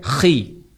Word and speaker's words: chi: X dalam bahasa Yunani bacanya chi.